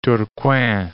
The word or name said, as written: Tourcoing